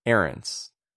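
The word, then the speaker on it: Ahrendts: I thought it was Ahrens. – Ahrens. Okay. Yeah.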